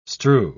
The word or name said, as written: strew